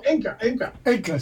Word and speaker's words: Enka: Can I ask if your parents were they into Anka? – Anka? – Anka. – What is that? Anka. – Japanese soul music. – Oh, Enka. – Sorry. Enka. Bad pronounce.